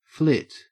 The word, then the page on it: flit: Australian English